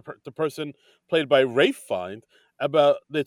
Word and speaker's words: Ralph: played by Rafe Fiennes or Ralph Fiennes, however you say it. Rafe. It's, it's pronounced Rafe.